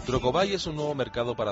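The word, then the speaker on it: Trocobuy: Trocobay se escribe, bueno, como suena, pero la última parte de la palabra buy es el verbo comprar, en inglés, B-U-Y, Trocobay.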